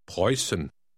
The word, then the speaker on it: Preußen: The letter you see after the U is pronounced like a double S. Try 15. – Preußen.